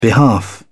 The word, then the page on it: behalf: British English